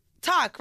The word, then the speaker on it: toque: How do you pronounce T-O-Q-U-E? – Just keep reading, dog. – T-O-Q-U-E. Toque Blanche. Toku. Take. – Where is the A? – Talk, right? Just talk.